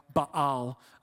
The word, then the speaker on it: Baal: It's actually pronounced more like Baal. I just pronounce it Baal because I'm a lazy American.